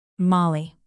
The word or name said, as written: mali